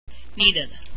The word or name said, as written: needed